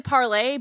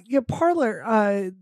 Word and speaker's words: Parler: I mean, it's technically Parlay, but we can call it anything. – Okay. I grew up in America, so I'm going to pronounce it the American way. Parler, they announced like that we're going to sue Amazon AWS service over this.